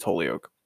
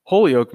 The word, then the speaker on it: Holyoke: I'm not sure exactly how to pronounce it, but I believe it's Holyoke.